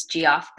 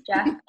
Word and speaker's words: Geoff: He spells it G-E-O-F-F. That's why everyone thinks it's G-off, but yeah, he's Jeff.